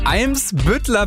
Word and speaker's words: Eimsbüttler: Mensch, wir reden über den Hamburger Eimsbüttler Ballspielclub.